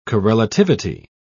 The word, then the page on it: correlativity: British English